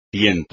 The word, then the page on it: tiento: espagnol